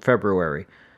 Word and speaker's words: February: So, and yes, I pronounced both R's in February, because there are two fucking R's in it.